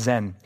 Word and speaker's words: Sin: This looks like sin, spelled like sin, is probably pronounced Zen,